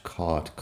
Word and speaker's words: caught: Fíjate que suena igual aparte de la primera letra ahí. Lo que pasa es que caught se escribe con C-A-U-G-H-T mientras que bought se escribe con B-O-U-G-H-T. En todo caso, la pronunciación de la vocal es igual en las dos.